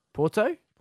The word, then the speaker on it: Porto: So this professional wrestler was forced to have emergency surgery after being hit with a brick during a match in Puerto? I'm terrible with these pronunciations. – Porto, yeah.